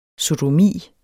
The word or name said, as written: sodomi